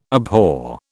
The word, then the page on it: abhor: British English